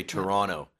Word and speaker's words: Toronto: But Canadians tend to say like I'm from around Toronto, the suburbs of Toronto, so we don't pronounce the last T. We say Toronto and Ottawa.